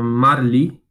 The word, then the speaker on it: Merli: Gente, eu vou encerrar aqui recomendando uma série que tem no Netflix, é chamada Marli, se escreve Merli,